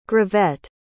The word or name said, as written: gravette